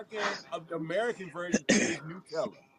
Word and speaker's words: Nutella: And the correct pronunciation is Nutella, but our version is Nutella, and there we go.